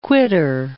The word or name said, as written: quitter